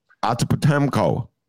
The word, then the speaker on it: Antetokounmpo: How do you pronounce Giannis's last name? – Antetokounmpo. – Antetokounmpo? – Yeah, it sounds good enough.